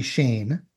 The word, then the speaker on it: Schoen: And he spells his last name S-C-H-O-E-N. But I believe it was still pronounced Bobby Shane. His real name, of course, was Schoenberger, S-C-H-O-E-N-B-E-R-G-E-R.